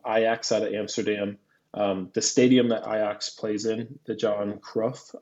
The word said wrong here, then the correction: Johan Cruyff: I believe I'm saying that right, stadium. – Johan Cruyff. – Yeah, yep.